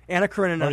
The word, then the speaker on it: Anna Karenina: You can just go online how do you call it? Anna Karenina? – Anna Karenina. – Or you can see the movie. – How do you say it again? – Anna Karenina. – Karenina. – Karenina. – Karenina. – Karenina. – I love that.